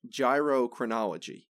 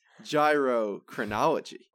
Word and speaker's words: gyrochronology: Another way to measure the age of a star is gyrochronology. Not gerontology. It's not gynecology. It's not geochromatography. No, it's gyrochronology.